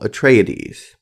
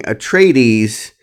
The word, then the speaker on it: Atreides: I knew that was going to happen, and apparently I was saying Atreides, and what listeners are telling me that there's an extra syllable, Atreides.